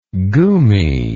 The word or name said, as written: gumi